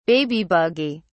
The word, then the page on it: baby buggy: British English